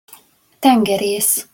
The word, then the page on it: tengeresz: Hungarian